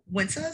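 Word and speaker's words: Wynta: So it's Winta. So it's W-Y-N-T-A and then Worthwhile. So W-O-R-T-H-W-H-I-L-E, all one word on Instagram.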